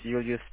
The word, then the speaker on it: Julius: My name is Julius, spelled with J-U-L-I-U-S, and the last initial L for Lima.